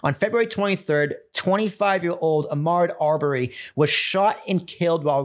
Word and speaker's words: Arbery: I would pronounce it Arbery. – Arbery.